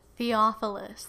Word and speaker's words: Theophilus: In my former book, Theophilus? How do you say that? – Theophilus.